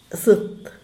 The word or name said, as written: zet